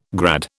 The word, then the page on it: grad: British English